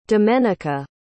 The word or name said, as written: domenica